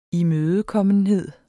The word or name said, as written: imødekommenhed